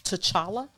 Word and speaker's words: T'Challa: And I'm glad to hear that they won't recast Chadwick's Boseman's T'Challa.